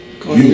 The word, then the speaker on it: Gogan: I had a hard time, but I think it's Gogan. – Gogan Mueller. Just think of G-O-N-E as opposed to the way it's spelled.